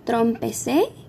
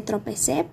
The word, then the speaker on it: tropecé: Trompecé, que en realidad se dice tropecé, pero algunas personas le agregan la letra M.